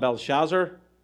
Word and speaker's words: Belshazzar: I'm going to call him Belshazzar. That's how I pronounce it. That's how the Irish pronounce it.